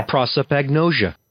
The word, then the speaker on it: prosopagnosia: Let me play what the internet says it's pronounced as. – Okay. – Prosopagnosia. – Prosopagnosia. Ooh.